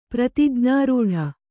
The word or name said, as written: ಪ್ರತಿಜ್ಞಾರೂಢ